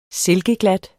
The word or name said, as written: silkeglat